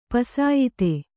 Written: ಪುಸಿಯೋತ

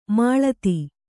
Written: ಮೆಲ್ಲಿದು

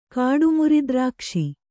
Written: ಕಾಡುಮುರಿದ್ರಾಕ್ಷಿ

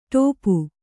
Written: ಟೋಪು

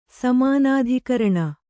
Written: ಸಮಾನಾಧಿಕರಣ

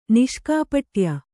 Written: ನಿಷ್ಕಾಪಟ್ಯ

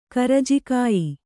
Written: ಕರ್ಜಿಕಾಯಿ

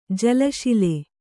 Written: ಜಲಶಿಲೆ

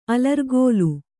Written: ಅಲರ್ಗೋಲ

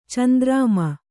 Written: ಚಂದ್ರಮ

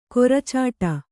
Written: ಕೊರಚಾಟ

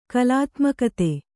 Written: ಕಲಾತ್ಮಕತೆ

